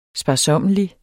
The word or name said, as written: sparsommelig